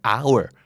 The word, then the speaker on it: hour: hour ที่หมายถึงชั่วโมงก็ไม่ออกเสียงตัว h เ